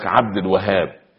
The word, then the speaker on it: الوهاب: الوهاب في شدة. عايز تكتبها باللغة الانجليزية دبل اتش اتش اتش الوهاب، هي كده بالعربي الهاء عليها ايه؟ على شدة. ولما ننطقها لازم نقول عبد الوهاب.